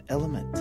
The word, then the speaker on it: LMNT: Elementant. – It's not French, right? Actually. It's the letters L-M-N-T, and it's pronounced Element.